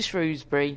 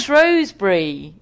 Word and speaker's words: Shrewsbury: We're going to Shrewsbury. – Yeah. – Why did I say it like that? – Hang on a minute. – Oh, my God. It's Shrewsbury.